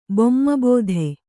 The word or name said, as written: ಬೊಮ್ಮಬೋಧೆ